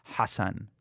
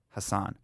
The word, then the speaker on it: Hasan: So it's like Hasan, like that's how they would pronounce my name. So for me, I split the difference and I was like Hasan, because there is still a contingency of people that go by the name H-A-S-A-N and pronounce it that way. So I was like, okay, I can live with that, but I can't do Hasan and I can't do Sahan.